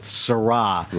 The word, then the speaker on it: Sirah: Petit Syrah shouldn't be confused with Syrah. – Right. – This is spelled S-I-R-A-H.